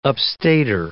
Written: upstater

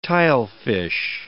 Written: tilefish

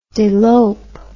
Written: delope

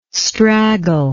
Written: straggle

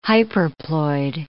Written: hyperploid

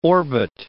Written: orbit

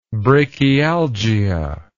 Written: brachialgia

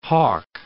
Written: Hawke Bay

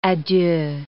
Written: a deux

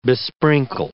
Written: besprinkle

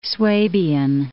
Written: Swabian

